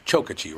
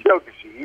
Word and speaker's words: Chokachi: Actually, it's pronounced Chokachi, right? – Chokachi. You got it.